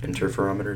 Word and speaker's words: interferometer: Interferometer, is that how you say it? – Yeah, interferometer.